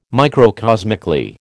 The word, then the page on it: microcosmically: American English